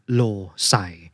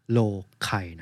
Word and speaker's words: Loci: ระสิทธิภาพเหนือกว่าการจำเป็นตัวหนังสือเยอะเลยนะครับวิธีนี้ชื่อว่า method of low s i หรือถ้าใช้ภาษาละตินดั้งเดิมจะอ่านว่า method of โลไค